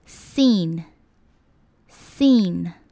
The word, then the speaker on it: scene: 接下来我们看到 scene， SC 的发音呢，一般来说就是 s 的音， scene scene。